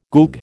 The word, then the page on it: goog: American English